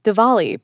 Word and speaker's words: Diwali: Diwali, pronounced with a V, is actually the Hindu Festival of Lights, which celebrates the triumph of good over evil and signifies renewal.